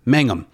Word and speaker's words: Mangum: And is it Jonathan Magnum? Is it Mangum? How do you say that? – Mangum.